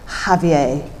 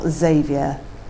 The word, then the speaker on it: Javier: She practiced on her tongue the Spanish pronunciation with a soft H instead of a Z. Javier, not Xavier. Like Jamon becoming Hamon.